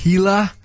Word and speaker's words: Gila: Apparently it's pronounced Gila.